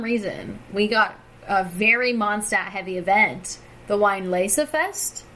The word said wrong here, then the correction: Weinlesefest: Which I think is actually pronounced Vine Lace.